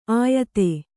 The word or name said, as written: ಆಯತೆ